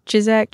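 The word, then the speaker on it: Ciszek: Sajak. – Walter Chizak. – Chizak. That's Chizak. It.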